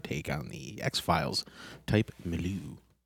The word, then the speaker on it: milieu: Yeah, that I mispronounced. It's milieu. – Milieu, yeah.